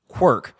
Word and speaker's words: Qwerke: That's Q W E R K E, pronounced Quirk.